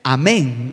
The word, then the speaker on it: Amén: Es, un, es una muestra de su lenguaje de conversión cuando usted dice Amén. Gloria al nombre de Jesús. Una palabra de apenas cuatro letras y con acento en la E.